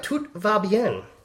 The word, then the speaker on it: Tout Va Bien: So we'll see how we go, but we have Tout Va Bien. Nice pronunciation.